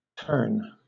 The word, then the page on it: terne: British English